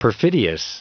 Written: perfidious

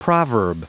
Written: proverb

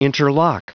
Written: interlock

